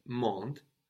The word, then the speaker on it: monde: So, mon is spelled M-O-N, no, and monde is spelled M-O-N-D-E. So we see again the E on the end of monde is what makes us pronounce that D, no? It's that vowel that activates the consonant before it.